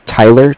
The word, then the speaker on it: Tyler: My name is Tyler, T-Y-L-E-R. And I'm with Bank of Cardiff. It's spelled C-A-R-D-I-F-F, as in Frank Frank.